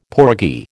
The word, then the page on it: porgy: American English